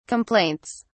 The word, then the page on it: complaints: British English